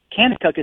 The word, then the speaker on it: Kanakuk: Kennecook is spelled K-A-N-A-K-U-K. Kennecook. Everything with a K there.